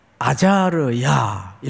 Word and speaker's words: 아자르야: 우시아, 우지야, 후 이렇게 발음을 할 수 있는데, 그런데 바로 아래 이름이 아자르야 이렇게 발음을 해요.